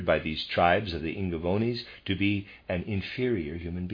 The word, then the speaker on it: Ingaevones: This came about because the temple priests of this secret mystery center on the Jutland Peninsula decreed that in certain tribes, the Ingavonis, as Tacitus called them, the sexual union of human beings must only take place during the first quarter of the year. Readers aside, I'm pronouncing I-N-G-A-E-V-O-N-E-S as Ingavonis.